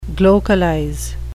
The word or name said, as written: glocalize